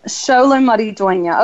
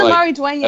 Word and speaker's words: Maridueña: Xolo Maridueña. Okay. Yeah, I got the Maridueña.